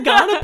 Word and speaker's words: Kaurna: It's spelt K-A-U-R-N-A. And we pronounce it Ghana.